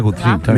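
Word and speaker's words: Gucci: Perdón, la pronunciación es Gutsi.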